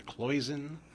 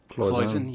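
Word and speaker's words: cloisonné: How do you say it? A cloison? C-L-O-I... – Cloison, yeah.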